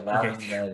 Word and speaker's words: Loudoun United: It is, yeah, Loudoun United.